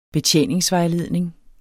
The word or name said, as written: BETJENINGSVEJLEDNING